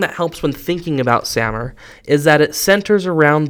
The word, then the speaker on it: SAMR: They are Sammer and TPACK. So Sammer, you'll also hear it called SAMR. I think both are acceptable pronunciations. I just call it Sammer.